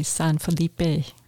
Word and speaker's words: San Felipe: My Spanish-speaking wife, how's it really pronounced? – Well, you would pronounce all the letters, so it'd be San Felipe.